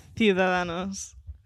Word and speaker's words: Ciudadanos: Ciudadanos.